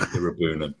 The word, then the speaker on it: Iroegbunam: He's, he's messaged me back saying Gerrard says it's a silent G, so I'd go with Iroegbunam.